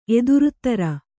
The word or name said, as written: ಎದುರುತ್ತರ